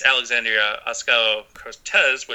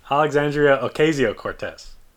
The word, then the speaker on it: Alexandria Ocasio-Cortez: Alexandria Ocasio-Cortez, which I know I just butchered her name. That wasn't done out of disrespect. I know Ocasio. – Henry mentioned, go ahead. What? – Alexandria Ocasio-Cortez.